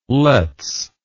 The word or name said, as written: lets